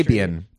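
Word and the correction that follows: Sybian: You mispronounced it too. It's Sibian.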